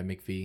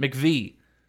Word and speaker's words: McVie: Is it McVie? Relevancy now. Yeah, McVie. – I think so. – Okay. I was trying to make sure I pronounced it correctly. Christine McVie. – Good job.